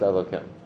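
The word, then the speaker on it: Elokim (אלוקים): If it's Elokim, if it's that Nikud under Yod Kei Vav Kei, then it's pronounced Elokim.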